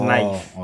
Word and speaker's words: knife: Oh, it's knife. – Okay. – The K is silent.